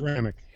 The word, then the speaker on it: Hamtramck: Hamtramck.